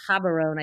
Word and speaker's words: Gaborone: I flew into Gaborone, or Gaborone, I think it's actually pronounced, which is the capital of Botswana.